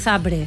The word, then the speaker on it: Zabrze: ¿Por qué sabes que es Zabrze? ¿Por qué pronuncias Zabrze? – Porque es así.